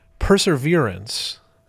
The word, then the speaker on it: perseverance: Well, I'm sure we will be soon corrected / informed by Marietta, who I should also mention has written in to tell us perseverance, accent on the third syllable, is a good thing. It means you don't give up on hard tasks. – Right, right. – Perseverance, accent on the second syllable, is when a person can't stop talking or thinking about something obsessively. It's not useful or good. So it's two words that are spelled the same way and yet pronounced differently and mean different things.